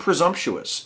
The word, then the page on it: presumptuous: American English